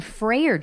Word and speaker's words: Freyer: Say it again. – Freyer. – Freyer. – Freyer Jones. – F-R-E-Y-E-R. – Yeah, but it's pronounced like, you know, as what? If it was F-R-A-Y-E-R. – Right. I've always thought of it as like Friar Tuck. – Right. – But it's not.